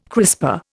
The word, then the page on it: crisper: Indian